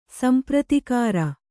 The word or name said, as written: ಸಂಪ್ರತಿಕಾರ